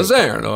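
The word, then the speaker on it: Mosaner: Italy, Stefania Constantini and Amos Mazzarino. Am I saying that right, Warren? Does that sound right, my Italian? – It sounds right, Jim. I think you got your Italian down pretty good. – Mazzarino, I like it.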